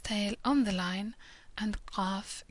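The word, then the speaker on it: Qaf (ق): Qaf is similar to Q, but pronounced further down in the throat. Qaf, like a little clicking sound almost.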